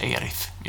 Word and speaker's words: Aerith: A-E-R-I-S. Aeris. Ja, fem bokstäver. Och det skulle in på lodrätt 12. Hon stavar Aerith, tror jag, och uttalas Erith i de nya versionerna.